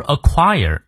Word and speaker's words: acquire: ever acquire acquire 注意它的重音